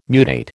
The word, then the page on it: mutate: American English